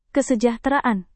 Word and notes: kesejahteraan: indonesian

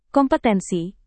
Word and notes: kompetensi: Indonesian

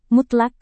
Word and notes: mutlak: Indonesian

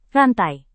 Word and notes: rantai: Indonesian

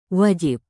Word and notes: wajib: Indonesian

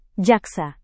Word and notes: jaksa: Indonesian

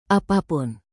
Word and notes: apapun: indonesian

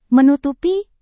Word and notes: menutupi: Indonesian

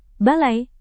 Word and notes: balai: indonesian

